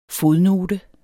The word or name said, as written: fodnote